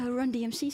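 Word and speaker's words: Run-DMC: Maar dat is natuurlijk eh, Run DMC, zeg ik dat goed?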